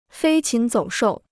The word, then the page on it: 飞禽走兽: fēi qín zǒu shòu